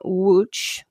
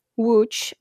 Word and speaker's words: Łódź: 1896 in Łódź, which is spelled L-O-D-Z, Łódź, in central Poland, to a Catholic family.